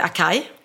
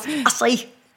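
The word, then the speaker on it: açaí: Och då uttalar jag det Akai och jag mm. gör ju helt fel. Jag gör en gillis. – Ja. Det, mm, det ska uttalas acai.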